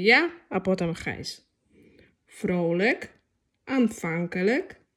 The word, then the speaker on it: Aanvankelijk: Pamiętajmy, że L i J K na końcu wyrazu wymawiamy jako lyk. I takie słówka mamy. Frolik. – Frolik. – Aanvankelijk.